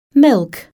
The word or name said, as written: milk